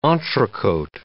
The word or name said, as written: entrecote